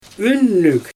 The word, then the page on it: ynnyg: Xyzyl